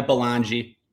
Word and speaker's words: Belongia: Yeah, Belongi.